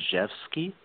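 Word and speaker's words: Rzewski: It's pronounced Jeffsky. It's a... – Seriously? Oh, wow. – Yeah. It's a Polish... I know, right? It's a Polish name. And so there's a lot of consonants that don't get pronounced.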